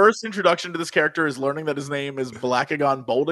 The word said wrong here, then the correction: Blackagar: Excuse me, it's Blackagar. – It's Blackagar. – What I say his name was? – I don't know. Something Blackagon sounds real Bolt silly. Again? – You said Blackagon.